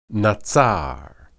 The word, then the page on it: natsar: Hebrew